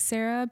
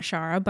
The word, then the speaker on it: Shara: Sarah because on my page, it's spelled S-H-A-R-A. – Yeah. – So it is Sarah. It's not Shara, but I do respond to Shara.